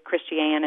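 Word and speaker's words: Pass Christian: And it is Christiane and not Christian is how we pronounce that down here.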